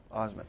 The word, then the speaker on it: Osment: Yeah, Osment.